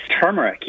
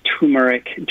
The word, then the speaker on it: turmeric: Yes, turmeric, yes. You can pronounce it any several different ways turmeric, turmeric.